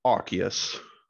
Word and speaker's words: Arceus: How to say the name of this Pokemon, Arceus.